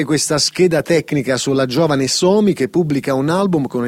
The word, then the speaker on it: Somi: Sì, il papà della signorina chiamata Somi, questa è la pronuncia esatta, no? – Sì, credo di sì.